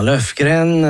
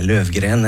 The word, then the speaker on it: Löfgren: Lyckliga gatan hörde vi med Anna-Lena Löfgren, eller Löfgren när det ska uttalas.